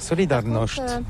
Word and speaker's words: Solidarność: Solidarność. – Là, je voudrais justement faire cette liaison entre le théâtre de Shakespeare et après Solidarność, le Centre européen de Solidarność.